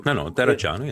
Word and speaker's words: Terracciano: I probably mispronounced his name there. – No, Terracciano, yeah.